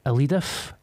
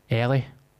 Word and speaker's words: Eilidh: Is it Elidif? Elif? – How do you spell it? – E-I-L-I-D-H. – Is that not like Ellie? – Ellie. – But just be... like the Gaelic or the Gaelic sort of?